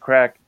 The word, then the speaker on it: craic: Which is so funny watching it, like, as Americans being like, oh, these guys keep talking about crack this whole movie. And they're just having a lot of fun. No crack is involved. – No, and it's spelled different, too. It's spelled C-R-A-I-C.